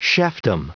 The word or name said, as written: chefdom